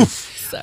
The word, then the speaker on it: Ulf: Ulf, U-L-F. He's Swedish. Samuelson. Danielsson. Oof. – That's how you say it. – How dare you? – It's oof.